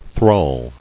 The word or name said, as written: Thrall